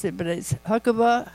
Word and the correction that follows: Habakkuk: How do you pronounce that, Paul? Habakkuk?